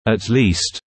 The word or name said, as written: at least